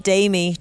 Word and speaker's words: Demi: Demi, is that correct? Orla, you were correcting my pronunciation. – Yeah. Well, because they all pronounce it Demi.